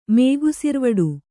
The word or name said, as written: ಮೇಗುಸಿರ್ವಡು